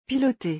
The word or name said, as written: piloter